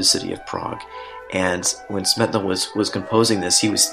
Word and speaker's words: Smetana: And that song, it was by Bedřich Smetana, or Smetana, depending on your pronunciation.